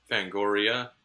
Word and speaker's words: Fangoria: No, because when I saw him at a a Fangora thing, and they were pronouncing it as Ungland. – Fangoria, you mean? – Yeah. – I don't know if I can trust your pronunciations if you're mispronouncing the thing that you're... – What did I say? I said Fangoria. – You said Fangora.